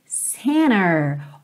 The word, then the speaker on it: center: Be with myself and center. Olha que interessante, super americana essa pronúncia center, que tá realmente omitindo a letra T.